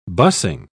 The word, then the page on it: busing: British English